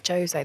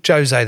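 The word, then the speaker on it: José: No, No, 'cause Portuguese. Portuguese. – What do you mean? – Jose. They pronounce the J's.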